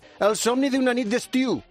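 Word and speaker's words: El somni d'una nit d'estiu: A ver si lo digo bien. El somni de una nit d'estiu. – Muy bien.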